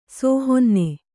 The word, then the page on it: ಸೋಹೊನ್ನೆ: Kannada